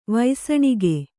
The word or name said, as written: ವೈಸಣಿಗೆ